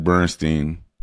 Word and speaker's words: Bernstein: Yeah, well, it's kind of easy to think about Leonard Bernstein, and I'm interchanging the second pronunciation of the second syllable because it's always been interchangeable.